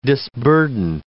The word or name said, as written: disburden